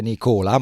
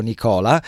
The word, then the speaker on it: Nicola: Dopo aver parlato di Tullio Plinio Gritti, il prossimo grab che vedo in linea è quello di Nicola, che non si dice Nicola, ma si dice proprio Nicola, che sì, è l'allenatore eroico, l'eroico allenatore che ha chiamato a risolvere missioni impossibili.